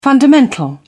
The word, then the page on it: fundamental: British English